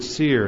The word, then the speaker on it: tsir: The first word is the Hebrew word tsir. It's spelled T-S-I-R.